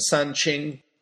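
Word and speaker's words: San Qing (三清): In Chinese, they say all that in two syllables. They're known as the San Qing. San, first tone, means three. Qing, well, among many things, means pure.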